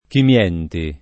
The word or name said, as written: Chimienti